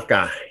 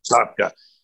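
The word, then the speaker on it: Safka: So the great singer, Melanie Sofka, if that's how you pronounce her name, correct me if I'm wrong. – Sofka, yep. – Sofka.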